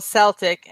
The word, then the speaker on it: Celtic: It's also Celtic and a hard C.